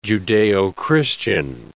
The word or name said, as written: Judeo-Christian